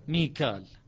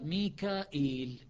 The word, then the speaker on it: ميكال: ميكال هذه قراءة حفص، كيف يقرأ حفص؟ ميكال. شعبة يقرأ ميكائيل كما ينطق بعض الناس، فتكون قراءة شعبة ميكائيل. الآن